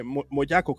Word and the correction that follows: Moyoco: creo que se llama. Eh, yo aquí la tengo apuntado, justo para que no se me olvide igual creo que lo dije mal. Moyoco, eh, Moyoko, Moyoko mm.